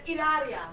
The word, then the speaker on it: Hilaria: Hilaria. – What she says to him. – How do you say it? – Hilaria. – Hilaria.